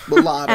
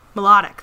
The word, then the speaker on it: Milotic: Milotic. – And Bulbasaur. Milotic, thank you.